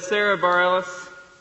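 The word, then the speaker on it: Sara Bareilles: In some ways, it is just that desire to have some of those sorts of conversations that led Sarah Bareilles.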